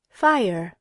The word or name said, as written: fire